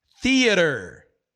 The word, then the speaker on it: theater: Theater. Theater. Não tem o home theater? Theater. Theater. Existem é, palavras em, em inglês que você precisa botar o, o, a língua entre os dentes.